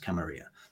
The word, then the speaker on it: Camarilla: Sometimes called the Camarilla, depending on who you ask, but properly pronounced Camarilla.